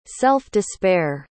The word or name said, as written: self-despair